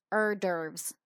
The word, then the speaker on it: hors d'oeuvres: Now, this is a tricky word to pronounce. It's a French word, and it's pronounced hors d'oeuvres. So the H is silent in this case.